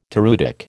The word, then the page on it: toreutic: American English